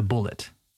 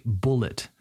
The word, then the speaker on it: bullet: We say bullet, not bullet. I've heard this too many times from students, okay? It's like the animal, bull, toro, bull, bullet, same vowel sound.